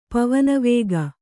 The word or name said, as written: ಪವನವೇಗ